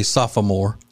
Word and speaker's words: sophomore: He will return a sophomore. Actually, sophomore. Yeah, there's an extra syllable in there. Sophomore, which a lot of people don't know.